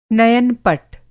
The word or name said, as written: नयन-पट